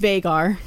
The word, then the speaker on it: Veigar: And that is how you say it, everybody. It's Veigar.